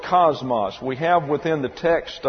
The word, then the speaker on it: kosmos: K-O-S-M-O-S. Cosmos. And when it comes over into the English, comes over into the English with a C here, a hard C, but it's pronounced cosmos. Well, these are omicrons and therefore get a short A sound, so it's really cosmos.